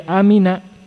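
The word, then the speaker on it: amin (آمين): Namun yang paling rajih amin bil mad wa takhfifil mim. Naam, dengan mad dan di apa namanya? ringankan mimnya, yakni maksudnya tanpa tasdid. Naam, tanpa tasdid.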